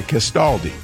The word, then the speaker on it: Castaldi: How do you pronounce that? Castaldi. Castaldi. – Yeah, Castaldi.